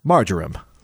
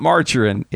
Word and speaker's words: marjoram: Marjoram. From the Marjoram Council. – Marjoram. It's not margarine, it's marjoram.